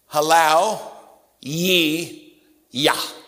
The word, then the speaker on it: hallelujah: It's kind of like the word hallelujah. That's the way we pronounce it. Hallelujah. There's another way to say it. Halal Yi Yah. Halal is the Hebrew word for praise. Yah, J-A-H, is a contraction of Jehovah or Lord.